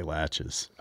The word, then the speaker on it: laches: How do you say latches? Laches? – I say latches.